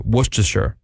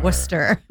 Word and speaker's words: Worcestershire: So you don't say the R, you don't say Worcestershire? – No.